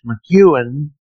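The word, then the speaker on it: M'Ewen: I believe we agreed that it's pronounced McEwen, but it's spelled M and then the hyphen E-W-E, and McEwen, Scottish one,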